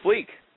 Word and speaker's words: Fleek: My original Twitter username was Chris Fleek 1. – Yeah, we don't like those ones, okay. And what did you change it to? – Chris Fleek. – And that's spelled C-H-R-I-S-F-L-E-E-K.